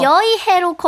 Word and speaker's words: 여의: 시프트를 누르시고 여의해로 검색을 해주셔야 돼요. – 조금 어렵겠다. – 네. – 헤이니. – 네. 네. – 발음 어떻게 해야 돼? 헤이니. – 쉐이니. 쉐이니예요. 여의. 네. 어의 아니고 여의.